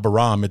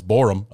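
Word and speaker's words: Borom: And then, you know, we saw Larry Borum, who I figured out that I've been pronouncing his last name wrong all this time. It's not Borum, it's Borum, apparently.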